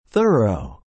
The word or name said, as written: thorough